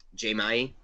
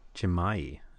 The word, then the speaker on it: Dzemaili: That's how you pronounce. it. I looked it up on YouTube. – Can we get that one again? – Dzemaili. – Dzemaili. All right. – It's like a yeah. The DZ makes a J.